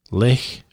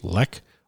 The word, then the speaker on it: Lech: Lech is how you say it, L-E-C-H, Lech, or Lech, Austria.